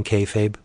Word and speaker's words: kayfabe: But Thundra has a tendency to break kayfabe. That's how you say it, breaking kayfabe.